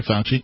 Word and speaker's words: Fauci: I really don't know much other than somebody leaked some emails from Anthony Fauci. Fauci. I keep saying it right.